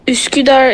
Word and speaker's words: Üsküdar: Üsküdar, that's how you pronounce it.